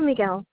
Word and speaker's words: Miguel: Just do Miguel Mesa. – M-I-G-U-E-L.